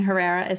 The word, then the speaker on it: Herrera: And Herrera is spelled H-E-R-R-E-R-A.